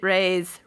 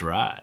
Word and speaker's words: Ray: Satyajit Ray's Rai? Ray? Satyajit Rai? – Are we going pure Bengali pronunciations? If so, then it's Rai. – But Jamie shamed me into calling him Ray months ago, so who knows? – I'm often wrong, but never in doubt.